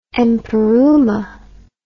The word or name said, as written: empyreuma